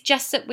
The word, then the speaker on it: Jessup: It is Jessup with a U though.